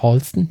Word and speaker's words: Halston: Und hier übrigens kleine Anmerkung, es war tatsächlich so, dass ich Halston die ganze Zeit falsch ausgesprochen habe letzte Folge. Es heißt Halston, nicht Halston.